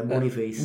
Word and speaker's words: Boniface: Ti correggo la pronuncia, sentendo telecronaca, Boniface.